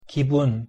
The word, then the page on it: 기분: Korean